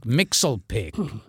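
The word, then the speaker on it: Mxyzptlk: The way it was pronounced to me for the cartoon was Mischievous Pickley.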